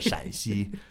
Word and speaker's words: Shaanxi: So it's not Shanxi, but Shanxi.